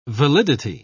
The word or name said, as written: validity